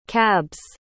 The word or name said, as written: cab's